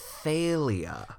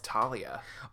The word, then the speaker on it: Thalia: Now, to be fair, it is spelled T-H-A-L-I-A, but my brain tells me that's Talia. – My brain always told me Talia, but now that that man said Thalia, I think it's wrong, but I like it better.